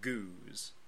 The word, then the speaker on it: Geuze: This one is called a Goose. – Goose. – It's spelled different ways. I think on the bottle it's Geuze.